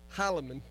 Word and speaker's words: Heilman: So anyway, Aaron Heilman, did I get it right? – Heilman, yes, sir. – Oh, I got it right. Anyway, the E is silent.